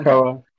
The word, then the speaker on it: Krakoa: Anyway, our next question comes from Jamie, at Jamie_season7, says, if Krakoa, I've never known how to pronounce this out loud, actually. – I want to say Krakoa. – Krakoa? – It's Krakoa. Okay.